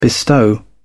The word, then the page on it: bestow: British English